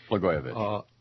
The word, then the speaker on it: Blagojevich: Blagojevich. Just think Blagojevich. Of the goy.